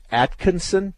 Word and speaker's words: Atkinson: Okay, so is it the correct pronunciation, Atkinson? – That's how I pronounce it.